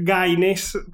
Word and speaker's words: Gaines: Estaba dándome cuenta que corría riesgo de pronunciarlo mal, pero es Gaines, todo otro apellido.